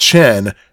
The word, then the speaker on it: Chien: I think it's pronounced Chen, but spelled C H I E N who, according to Twin Galaxy, is currently the number two world record holder of Donkey Kong.